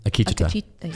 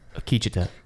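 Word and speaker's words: Akecheta: Did I say it wrong again? – Akicheta. – Fuck. Akicheta.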